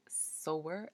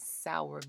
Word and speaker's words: sower: And funny story, before I actually read the book, I was pronouncing sower as sour because that was the word that was used when the book was recommended to me. So I was saying parable of the sour, which is incorrect.